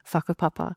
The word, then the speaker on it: whakapapa: So the W and the H are pronounced as an F. So it's whakapapa. So that is essentially the gift of genealogy, the gift of what makes us Māori.